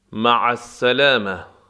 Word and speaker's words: مع السلامة: مع السلامه، مع عین دارد و از وسط حلق تلفظ می شود.